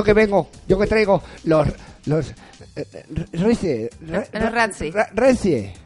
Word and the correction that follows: Razzie: No, no, se escribe Razzie. – Razzie.